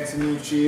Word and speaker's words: Antonucci: Nah, man, Antonucci is, you know, it's a name that comes from the Roman Empire, believe it or not.